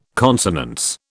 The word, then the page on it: consonance: British English